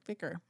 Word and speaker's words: Vicar: Vicar.